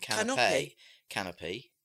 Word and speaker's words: canapé: C-A-N-O-P-Y is canopy. And canapé. And canapé is C-A-N-A-P-E with an accent over it. So they're spelled differently but pronounced the same. – Yes, because of the... Well, not really. Canapé and canopy. They're not really the same, are they? – Canapé.